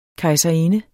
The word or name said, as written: KEJSERINDENS